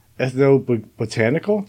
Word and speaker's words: ethnobotanical: He has conducted, I don't know if I'm going to say this word right, ethnobotanical. Did I say that right? – You got it.